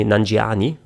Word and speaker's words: Nanjiani: Kumail Nanjani, äh, oder nee, Nanjiani wird es ja ausgesprochen.